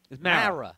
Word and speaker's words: Mara: And when JR wasn't fired, I knew McAdoo would be our coach because Myra was not – is it Myra or – Mara. Mara. – M-A-R-A.